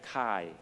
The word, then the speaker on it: Chi: 第二個好像 X 的字呢，其實就是英文的 Christ，即是基督那個 Christ 呢，那個 CH 呢其實對應回個希臘字母是有 X 這樣的形象，但這個不是 X 來的，其實個發音是 Chi 這樣的音的。